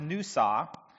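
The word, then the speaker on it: nusa (נשא): The word is N-A-S-A, and it's pronounced nusa, and it just means, you know, lift up, basically.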